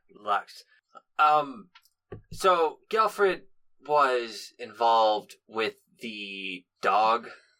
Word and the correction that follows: Gilfred: And Gilfred? You keep saying his name wrong. – Gilfred.